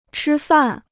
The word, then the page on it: 吃饭: chī fàn